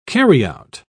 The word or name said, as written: carryout